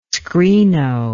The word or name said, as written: screeno